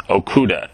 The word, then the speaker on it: Okuda: It's Michael and Denise Okuda. Am I saying that name right? – You are indeed.